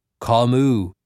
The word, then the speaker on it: Camus: Camus, I said aloud, butchering the name. It's French, man. Camus.